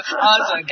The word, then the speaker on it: Aza: Well, at least we have the right pronunciation now, because when Hank mentions it during our On the Bandstand segment, he says Aza. So Aza, got it.